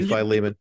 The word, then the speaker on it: Philemon: And yet I think a lot of people will realize that they've kind of, I mean, let's face it, the book of, do you pronounce it Philemon? Is that the pronouncer? – That's the proper. – Well, we're two or more are gathered, then God has to bless it. So we both say Philemon.